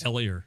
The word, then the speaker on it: Elier: I butcher it since he's been there. Eler Hernandez. – Elier. Elier Hernandez who came up.